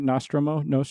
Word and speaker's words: Nostromo: Nostromo.